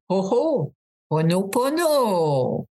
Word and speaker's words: Ho'oponopono: Pensez en premier au Père Noël. Ho, ho. Deuxièmement, pono, pono. Ponono Ponono. Alors, c'est ho ho, pono Et là, vous allez être correct pour bien le prononcer.